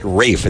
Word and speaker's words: Ralph: Now, Ralph, you for years have had it out for him because his name is spelled the same as yours, yet he pronounces it Rafe, and that was unacceptable to you.